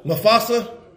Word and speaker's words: Mufasa: Mustafa. On July, did I say it right? Mufasa. Mufasa. The Lion King.